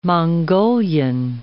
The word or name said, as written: Mongolian